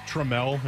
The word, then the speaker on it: Trammell: I think it's Trammell is how you pronounce his name.